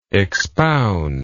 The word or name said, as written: expounder